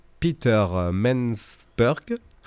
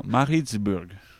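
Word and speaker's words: Pietermaritzburg: Peter Mansburg. – Maritzburg.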